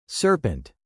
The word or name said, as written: serpent